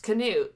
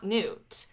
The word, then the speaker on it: Knute: his name is pronounced Knute, not Knute. And apparently that's how they pronounced it in the film as a result. – Oh, okay. – So it is not Knute Rockne, it is Knute Rockne.